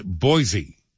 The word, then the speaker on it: Boise: Boise, Idaho is pronounced Boise.